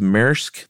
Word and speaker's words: Maersk: And I think, is it pronounced Maersk, the big shipping company? – Yeah.